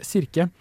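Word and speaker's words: Kirke: Eh, jeg klarer ikke helt å uttale det. – Sirke? – Sirke! Å, oh, det, eh, det, er riktig uttale.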